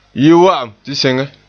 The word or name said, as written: yi